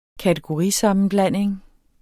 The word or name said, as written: kategorisammenblanding